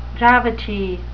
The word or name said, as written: gravity